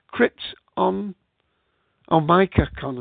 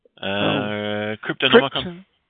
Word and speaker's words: Cryptonomicon: Crypt on Micacon or something. – Cryptonomicon.